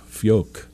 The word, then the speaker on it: Fjok: Fjok, for those of you who are not Scandinavian, the J is pronounced like a Y.